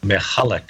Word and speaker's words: Michalak: Michalak, yes.